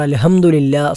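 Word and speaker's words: അൽഹംദുലില്ലാ (الحمد لله): സുബ്ഹാനല്ലാ, അൽഹംദുലില്ലാ, ഇവിടെ രണ്ടും ഹാ എന്ന അക്ഷരമാണ് ഇവിടെ ഉച്ചരിക്കേണ്ടത്. ഹാ എന്ന അക്ഷരം തൊണ്ടയുടെ മധ്യഭാഗത്തു നിന്നും പുറപ്പെടേണ്ട അക്ഷരമാണ്. സുബ്ഹാനല്ലാ, സാധാരണ ആളുകൾ സുബ്ഹാനല്ലാ എന്ന് കെട്ടുള്ള ഹാ തൊണ്ടയുടെ ഏറ്റവും താഴ്ഭാഗത്തു നിന്നും പുറപ്പെടുന്ന അക്ഷരമായി ഉച്ചരിച്ചു വരാറുണ്ട്.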